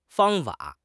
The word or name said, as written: fang1 fa3